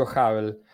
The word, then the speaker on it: Hubble: El Hubble, el telescopio Hubble, se niega a retirarse. Ah, sí, Sí, sí, sí, bueno, este, no te entendí, lo, pronuncias muy bien, yo digo Hubble, pero el, telescopio Hubble tenía una obsolescencia creo que hace unos 5 o 10 años.